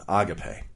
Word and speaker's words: agape: Now, the first fruit of the Spirit that Paul lists is love, agape. That is the proper pronunciation, agape.